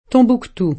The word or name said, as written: Tombouctou